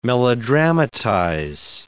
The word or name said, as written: melodramatize